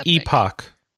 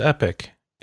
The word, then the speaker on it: Epoch: You're going to say, but it's pronounced Epic. And you're right. But that's not how they pronounce it in the game. The device is called the Epoch.